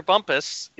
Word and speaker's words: Bumpass: Did you guys cover Roger Bumpass at all? I know I'm saying his name wrong. – Yes, so Roger Bumpus is the voice of Squidward from SpongeBob SquarePants.